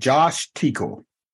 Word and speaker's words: Teekell: I'm your host and I am here with Josh Teekle. Did I say it right? – Nailed it.